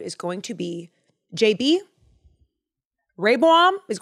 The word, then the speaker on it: Rehoboam: Rehoboam, I said it wrong again. Rehoboam is Solomon's son.